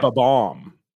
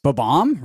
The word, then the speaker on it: Bob-omb: Bob-omb, really? – Bob-omb, yeah. – Wow. I guess it's true because like are you emphasizing the Bob or the bomb?